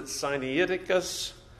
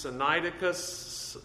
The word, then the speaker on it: Sinaiticus: I've always pronounced it Sinaiticus, but I've heard others pronounce it different ways. Sinaiticus, Sinai, of course, is the beginning of that word. It's where it was found at the foot of Mount Sinai at St. Catherine's Monastery, and so it gets its name from that. And so Sinai, Sinaiticus, I'm not sure the pronunciation.